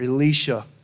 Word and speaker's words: Elisha: No coincidence today, the Old Testament was about Elisha, or Elisha, as Father Mark pronounces it.